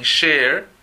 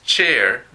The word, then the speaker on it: chair: Look at the chair, dicen share cuando es chair de silla.